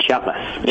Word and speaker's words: Chiapas: He's the author of Intimate Enemies, Landowners, Power, and Violence in Chiapas. I don't think I pronounced that correctly. Anyway, please welcome Aaron Bobrow-Strain. How do you say that? – Thanks for having me. It's Chiapas. – Chiapas.